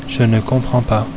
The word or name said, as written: Je ne comprends pas